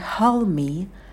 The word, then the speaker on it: hold me: «Обними меня» – это hold me. Hold me, а звук «д» также может выпадать.